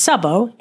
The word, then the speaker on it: Szabó: It's Szabó, S-Z-A-B-O, but it is routinely misspelled and mispronounced.